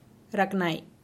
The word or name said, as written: raknai